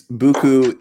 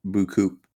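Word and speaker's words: beaucoup: So it's Buku is the butchering, the American slang butchering of the French word Bukoop.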